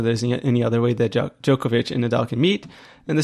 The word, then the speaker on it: Djokovic: And really, it's Djokovic. I keep saying it wrong.